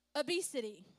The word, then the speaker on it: Abcde: It is simply A, B, C, D, E, okay? A, B, C, D, E. This is pronounced obesity.